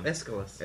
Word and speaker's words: Aeschylus: help me out here, Aeschylus? – Oh, I can never pronounce it. – That's a tough one. – Aeschylus. – Yeah. Okay, so yeah, I did it right.